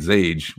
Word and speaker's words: Xage: So it's not, this is not a cool go look at Zage, which is spelled X-A-G-E, just for the record.